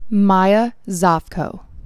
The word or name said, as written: Maya Zovko